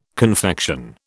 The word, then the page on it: confection: British English